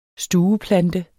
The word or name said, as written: stueplante